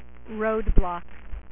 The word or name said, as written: Roadblock